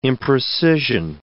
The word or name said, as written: imprecision